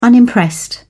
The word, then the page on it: unimpressed: British English